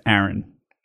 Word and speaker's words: Aaron: The woman's name is Erin. The man's name is Aaron.